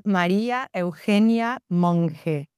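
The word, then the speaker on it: María Eugenia Monge: Softer. The G sounds softer. It's Maria Eugenia Monge.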